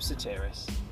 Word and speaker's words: Sotiris: Jacob Sotiris. And Jacob is spelled J-A-C-O-B, Sotiris is spelled S-O-T-I-R-I-S.